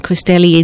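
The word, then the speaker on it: Christelli: And then straight away, my next name, which is C-H-R-I-S-T-E. LLI. But if you type in Sabine Cristelli in something similar, I'll come up and sleep, because I'm in Adelaide and it's easy to find.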